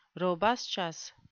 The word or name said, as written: robustious